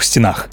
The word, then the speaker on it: стенах: Вообще здесь ударение вариативно. Можно сказать «стенам» и «стенам», «стенами» и «стенами», «стенах» и «стенах». Но Идеальный вариант все-таки с ударением на окончании, то есть стенам, стенами, стенах, а в устойчивых выражениях это вообще единственный возможный вариант.